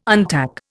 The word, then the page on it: untack: Indian